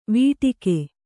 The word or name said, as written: ವಟಕ